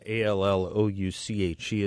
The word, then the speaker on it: Allouche: Allouche is how he pronounces his name.